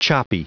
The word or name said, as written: choppy